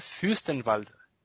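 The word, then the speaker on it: Fürstenwalde: Mira, el Wolfsburg derrotó 4-1 al Unión Furstenwald. ¿Lo dije bien? Ahí, más o menos, además. – Más, más o, menos. ¿cómo es? – A ver. Unión Fürstenwald. Fürstenwald. Ahí va, ahí va.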